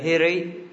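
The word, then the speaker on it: hiri: Ngày hôm qua chúng ta có cái vô tam là a hi ri cả, và bây giờ hi ri, đọc là hi ri, là tam. Hai chữ y này đọc ngắn lại, chứ không phải là thêm dấu hỏi, dấu sắc gì cả, mà đọc thay vì hi thì ta đọc hi.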